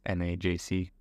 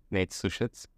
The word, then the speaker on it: Nejc Susec: Yeah, anywhere online, I use my name, which is Nejc Susec, Nejc Susec, which is difficult to guess from my pronunciation.